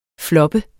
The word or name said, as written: floppe